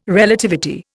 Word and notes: Relativity: Indian

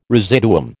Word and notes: residuum: American English

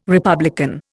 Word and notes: republican: Indian

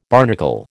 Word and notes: barnacle: American English

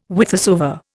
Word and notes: whithersoever: Indian

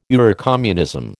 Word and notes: Eurocommunism: American English

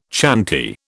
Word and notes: chanty: British English

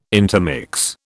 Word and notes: intermix: British English

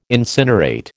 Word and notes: incinerate: American English

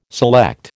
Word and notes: select: American English

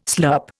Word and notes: slurp: Indian

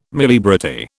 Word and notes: muliebrity: British English

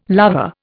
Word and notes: lover: Indian

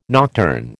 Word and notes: nocturne: American English

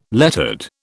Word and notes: lettered: British English

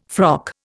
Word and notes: frock: Indian

